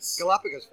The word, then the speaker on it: Galapagos: I mean, I fed a tortoise today from the Galapagos, is that how you say it? – Galapagos, thank you very much for putting me right. Again.